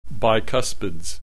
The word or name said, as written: bicuspids